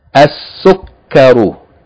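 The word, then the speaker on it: As-sukkaru (السكر): As-sukkaru, as-sukkaru, with shadda on the calf, as-sukkaru, meaning the sugar or sugar. Seen is a sun letter, the seen should be doubled, the lam should not be pronounced.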